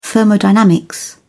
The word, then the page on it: thermodynamics: British English